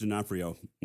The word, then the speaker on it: D'Onofrio: Is it Vincent D'Orfanio, Vince D'Onofrio. Mm-hmm.